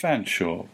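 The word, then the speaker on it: Featherstonhaugh: This gentleman, who was actually born in France but was very British, gloried in a name which was Rupert Edward Lee, what looks like Featherstone Hall, but I believe is pronounced Fanshawe, because English is like that.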